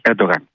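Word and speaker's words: Erdoğan: Μέχρι να έρθει ο κύριος Ερντογάν, που είναι η σωστή προφορά με τα τουρκικά της Ανατολίας,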